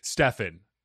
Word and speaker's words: Stephen: Do you pronounce it Stefan or Steven? – Stefan.